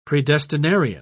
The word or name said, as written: predestinarian